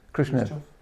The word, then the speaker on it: Khrushchev: How do you pronounce it? Khrushchev. Khrushchev, yeah.